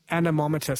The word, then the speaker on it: anemometer: Harry has just said it's pronounced anemometer.